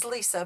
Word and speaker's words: Lesa: My name is Lisa, but it's spelled L-E-S-A.